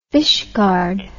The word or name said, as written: fishguard